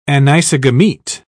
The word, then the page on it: anisogamete: British English